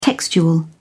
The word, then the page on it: textual: British English